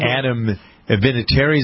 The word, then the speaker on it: Vinatieri: And Adam Vinatieri, is that how you pronounce his name? – Yeah.